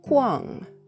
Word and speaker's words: 矿: これはですね、漢字で書くとですね、あの、まず 3 つの漢字で構成されていまして、最初の文字は、コアン、コアンというふうに発音します。石に、日本語で言うところのまだれみたいなのがついてるんですね。これで、ホアン、発音記号は K-U-A-N-G というふうに綴ります。